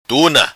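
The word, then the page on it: Tuna: Macanese Patuá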